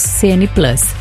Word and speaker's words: CN Plus: CN Plus se escreve, né? Mas nós pronunciamos CN Plus.